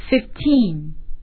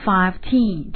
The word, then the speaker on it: fifteen: 那么十五是稍微有点特殊的，我们要读 fifteen。啊，不能说成 fifteen，这样是不对的。